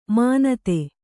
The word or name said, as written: ಮಾನಿತ